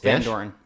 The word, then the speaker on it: Vandoorne: Vandoorne.